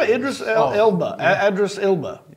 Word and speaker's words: Idris Elba: Idris Elba? – Oh, yeah. Idris Elba.